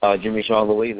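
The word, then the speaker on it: Jimmy Jean-Louis: Jimmy Jean Lewis, that's the French pronunciation. He's Haitian.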